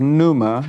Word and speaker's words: pneuma: Now, even as we go through this, remember the Greek word for Spirit, in case you want to know, is pneuma. It's spelled P-N-E-U-M-A. It's like you hear about pneumatic drills or pneumatic tires or what have you like that.